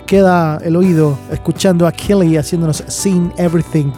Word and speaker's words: Keley: No todo encaja con el bailar pegados, pero acá les tengo a Kelly, se escribe K-E-L-E-Y, Kelly, un nuevo single llamado Sing Everything.